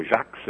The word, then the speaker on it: Jacques: o Jacob Bernoulli, ou Jacques, se a gente quiser usar a pronúncia afrancesada do nome, ele escreveu um livro há 304 anos atrás.